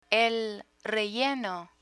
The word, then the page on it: relleno: Spanish